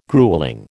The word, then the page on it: gruelling: American English